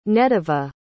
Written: nediva

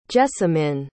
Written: Jessamyn

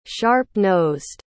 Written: sharp-nosed

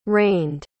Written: rained

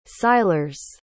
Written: Silures

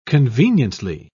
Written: conveniently

